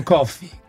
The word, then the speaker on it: Coffey: Senti, ma volevo dire, John Coffey come la bevanda? Cos'è che dice lui nel film? Mi chiamo John Coffey. Si pronuncia come la bevanda, si pronuncia uguale.